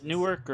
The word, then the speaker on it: Newark: Murder spree in downtown Newark. – Uh-huh. Yeah, exactly. – Did I say it correctly? Was it Newark or... – It's Newark.